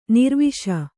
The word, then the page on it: ನಿರ್ವಿಷ: nirviṣa